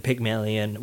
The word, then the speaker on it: Pygmalion: Pygmalion? – Yeah, Pygmalion.